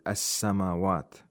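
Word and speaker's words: as-samawat (السماوات): Now, in the phrase rabbu samawat, we have a sun letter after the alif lam in the word as-samawat. So the lam is not pronounced. Instead, the sun letter becomes mushaddad.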